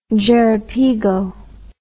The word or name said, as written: jerepigo